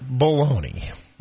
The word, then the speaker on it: bologna: The word is B-O-L-O-G-N-A. – I say it baloney.